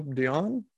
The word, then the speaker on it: Dionne: Her name was Elzira uh, Elzira Dionne. Uh, I, I don't know if it's, I guess it's pronounced Dion, like Celine Dion, who I think is also Canadian, maybe.